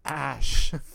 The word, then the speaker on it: Ash: Rhymes with sash. – Ash.